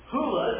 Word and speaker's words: Hula: And Lake Hula, Hula is how it's pronounced, like hoop.